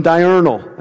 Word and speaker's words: diurnal: Nocturnal and diurnal. I think I'm saying that right. Am I saying that right? Yes.